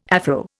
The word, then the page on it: Afro: Indian